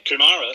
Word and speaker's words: Kumara: Yeah, Kumara, that's the one.